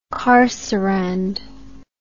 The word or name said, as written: carcerand